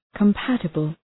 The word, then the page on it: compatible: kəm’pætəbəl